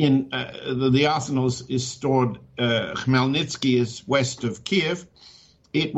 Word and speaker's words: Khmelnytsky: Well, now to get to your article, you cite and I'll pronounce it as best I can, the Melnitsky? – I'll pronounce it for you because Chris Busby misspelled it. And I had to respell it. It's Khmelnytsky. Khmelnytsky